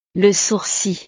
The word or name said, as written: le sourcil